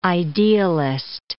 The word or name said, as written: idealist